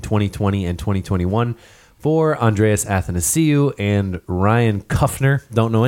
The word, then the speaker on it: Athanasiou: You hate saying his name right. – I love saying his name right. – Athanasiou. No, it's Athanasiou. Look at A-T-H-A-N-A-S-I-O.